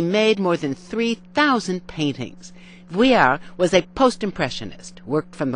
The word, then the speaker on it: Vuillard: His name is Edward Vuillard, pronounced Vuillard. There's no D at the end.